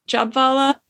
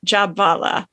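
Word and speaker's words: Jhabvala: We've been thinking the whole time it was Ruth Prawer Jabvala, but because she was from Germany and they pronounce the W as a V, I believe it's Ruth Prawer Jabvala.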